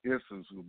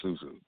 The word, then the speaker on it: susu: Creating a rental housing SUSU, and a SUSU, not ISUSU, but the word SUSU is spelled, or ZUSU, I say SUSU. It's spelled S-U-S-U.